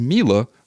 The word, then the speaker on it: Miele: And I figured out the proper pronunciation of the company is Miele when I called.